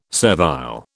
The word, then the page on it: servile: British English